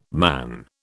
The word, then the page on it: man: British English